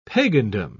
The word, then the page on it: pagandom: British English